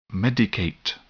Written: medicate

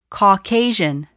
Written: Caucasian

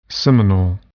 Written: seminal

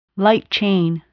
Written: light chain

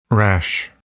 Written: rash

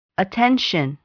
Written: attention

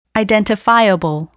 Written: identifiable

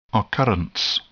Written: occurrence

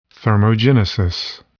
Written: thermogenesis

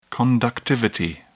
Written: conductivity

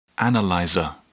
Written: analyzer

